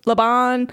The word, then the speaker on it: Laban: He comes up to these people and he's like, hey, do you know Laban, the son of Nahor?